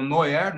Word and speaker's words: noir: Se você for para a França, o noir né? acho que é noir a pronúncia correta, que é N-O-I-R, você traduz, você traduz para preto.